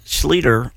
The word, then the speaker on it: Schleter: Saw him in concert a few weeks ago, and he said it's Schleter, like Peter.